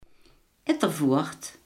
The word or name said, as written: Ittervoort